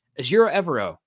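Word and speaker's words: Ejiro Evero: So Azure Evero, coming back to him, said the name right, Forever Evero. That's how I remember it.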